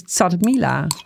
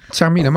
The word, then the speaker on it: chermoula: Toen zei ik het is Tsarmila. – Tsarmila, maar... – Ja, zo spreek je het uit.